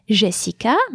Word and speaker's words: Jessica: is pronounced E, as in Jessica, my name, or merci.